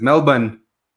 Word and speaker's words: Melbourne: Este, eh, César Albarrán nos está saludando desde Melbourne, que si lo dije mal, por pensar en decirlo bien, lo dije mal. Te regañan la gente de Melbourne si lo pronuncias más, es Melbourne. Si no te regañan.